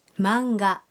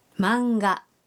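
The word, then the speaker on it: マンガ: هذه القصص المرسومة تنطق هكذا مانجا مانجا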